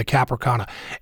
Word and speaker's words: Cap Arcona: Well, I want to take a step back before we get to that final tragic chapter in the story of the Cap Arcona. And I am pronouncing that correctly, am I not? – Yes, you are.